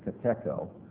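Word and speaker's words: katecho: It's K-A-T-E-C-H-O, kateko. Actually, I think the Greek is, to be correct, is pronounced kateko, but the catechism puts the emphasis on the first syllable.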